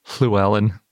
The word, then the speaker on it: Llewellyn: But if you speak Welsh, you might be familiar with it because it's basically pronounced the same as the double L in Welsh, like Llewellyn, if you say it in the Welsh way, which I think is something more like Llewellyn, but I don't speak Welsh.